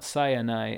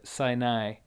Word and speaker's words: Sinai: So the mountain is Mount Sinai, and not Mount Sinai, as many people say. The word is with the A in the second syllable, so Sinai.